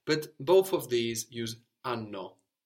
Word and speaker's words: hanno: So we get sanno, they know, from sapere, and hanno. H-A-N-N-O, and of course the H is silent, hanno, for they have, from avere.